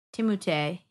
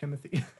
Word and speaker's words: Timothée: Is it Timothée? – Timothée. He's French. – I thought it was just Timothy. – It is. He's French. It's It's technically Timothée.